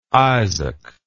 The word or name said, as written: izzak